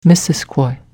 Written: Missisquoi

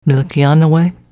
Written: Milkeanaway

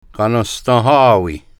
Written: Kanenstenhawi

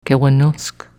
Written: Kewenusk

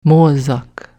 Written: moozak

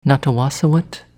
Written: Nattawassawet

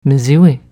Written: mziwi

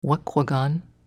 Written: Wequagan

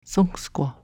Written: sunksqua

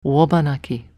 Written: Wobanaki

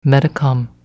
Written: Metacom

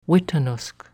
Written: Weetanusk

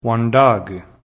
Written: Wendake

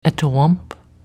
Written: Etowamp

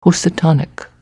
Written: Housatonic

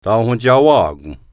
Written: Thaonwentsawakon

